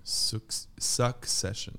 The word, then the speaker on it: Succession: succession. – Succession. – Succession. – Succession. Ahí está, esa, no su. Succession.